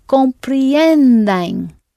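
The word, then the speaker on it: compreendem: This is the present tense, so it should be comprienden.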